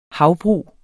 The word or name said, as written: havbrug